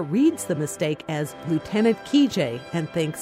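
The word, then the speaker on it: Kijé: Lieutenant Kijay, or Kijay as it's pronounced in Russian, was a book and then a movie about an army officer who never existed.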